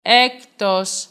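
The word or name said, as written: έκτος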